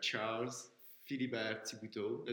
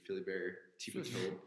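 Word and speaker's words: Charles Philibert-Thibeault: Charles Philibert Thibuteau. That's how we say it in French, but I guess in English could be Philibert Thibuteau.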